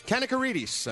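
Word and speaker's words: Kanakaredes: Kanakarides, I'm getting in my ear. Kanakarides is right. How you say it.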